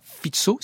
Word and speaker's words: Fico: La Slovaquie est dirigée depuis cet automne par le leader populiste Robert Fico, plutôt Robert Fico, si on veut le prononcer euh, à la Slovaque, adepte de méthodes autoritaires pour diriger le pays.